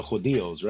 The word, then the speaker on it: judíos: reaching the Jews or the Judíos, right? – Yes.